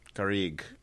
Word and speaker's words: Garrigue: Garrigue.